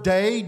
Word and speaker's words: dei: The Greek verb dei, D-E-I, is how we would spell it, is what's used in verse 16 by Jesus.